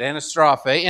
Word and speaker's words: anastrophe: That word conversation, if you dig into it a little bit, it has this, well, here's the Greek word, anastrophe. It's how you say it, anastrophe.